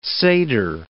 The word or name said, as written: seder